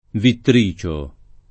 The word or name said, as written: Vittricio